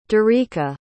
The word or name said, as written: darika